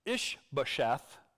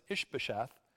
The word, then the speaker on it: Ish-bosheth: Okay, meanwhile, Abner, son of Ner, the commander of Saul's army, had taken Ish-bosheth, that's how you pronounce it, Ish-bosheth,